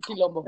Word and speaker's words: quilombo: Colombo. ¿Cómo se dice? – El Quilombo.